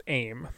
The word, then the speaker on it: Aym: So the other attendant to the one who waits is known as Aim, which is spelled A-Y-M.